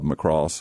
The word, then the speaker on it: Macross: He was Cowboy Bob Macross, which rhymes with floss.